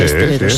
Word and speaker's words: esteleros: Dice Inma, en Canarias a los componedores de huesos se les llama esteleros. ¿Lo he dicho bien? Sí, sí, esteleros. Sí, esteleros, sí.